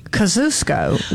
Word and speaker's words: Kosciuszko: And they say it Kosciuszko, which is... probably the correct way to say it, but that's not how we say it in Mississippi.